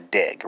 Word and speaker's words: dig: But we need the pronoun, so that's a dig, right? But it's spelled D-I-G in Swedish.